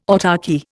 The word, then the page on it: autarchy: Indian